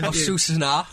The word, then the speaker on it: Osasuna: Osasuna.